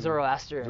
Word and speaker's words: Zoroastrian: was like, uh, just uh, Zeus, how do you say that? Zeusterian? – Zeus, Zoroastrian. Zoroastrians.